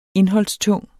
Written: indholdstung

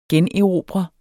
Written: generobre

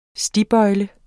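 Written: STIGBØJLE

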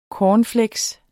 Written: CORNFLAKES

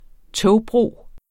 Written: togbro

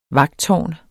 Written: vagttårn

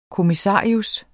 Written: kommissarius

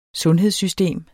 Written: sundhedssystem

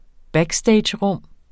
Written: backstagerum

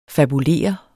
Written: fabulere

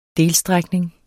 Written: delstrækning

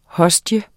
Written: hostie

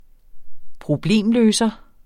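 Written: PROBLEMLØSER

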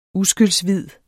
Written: uskyldshvid

